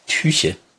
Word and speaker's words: Tyche: Fun Fact, Tyche wird Tyche ausgesprochen und Tyche ist in der griechischen Mythologie die Göttin des Schicksals, der glücklichen oder unglücklichen Fügung und des Zufalls.